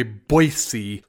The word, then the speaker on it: Boise: Everyone says there's no Z in it, and they get irritated if you don't say Boise.